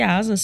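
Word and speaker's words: Asaas: Lembrando que Asas se escreve A-S-A-A-S e conheça a conta digital completa para empresas.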